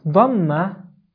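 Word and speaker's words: ضمة: Again, in the pronunciation of Dhamma, there is an important point, and that is that Dhamma is pronounced exactly like oo, like oo, but very short. It's not like o. So as in English we have o and u. O u, as we say in this sentence. O u. So the first one is o. The second one is u. U. This u is the exact pronunciation of dhamma in Arabic, but it's short.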